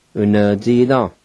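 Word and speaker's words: unvtsida: Number four is brain, u-na-ji-dan, u-na-ji-dan.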